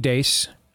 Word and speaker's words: Deace: And then at Steve Dace on Truth Social. I'm sorry, at Real Steve Dace on Truth Social. DEACE is how to pronounce the last name.